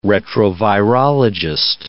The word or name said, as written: retrovirologist